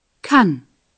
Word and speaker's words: kann: In the singular, the vowel is an A, pronounced here A. – Kann. Ich kann.